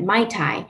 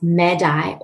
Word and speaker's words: Mai Tai: it's actually not pronounced Mai Tai. It's pronounced MedEye or MedEye, depending on the dialect that you're using.